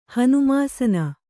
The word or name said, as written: ಹನುಮಾಸನ